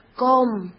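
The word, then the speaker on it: Qom: Y también la pronunciación de la cultura toba, digamos, es com. – Es com. – Y se escribe así tal cual, es una Q, una O y una M. Com, que significa nosotros.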